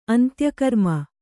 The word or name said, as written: ಅಂತ್ಯಕರ್ಮ